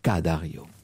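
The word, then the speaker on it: Ca' Dario: Et nous poursuivons notre promenade vénitienne et nous sommes maintenant précisément al Palazzo Dario ou la Ca Dario.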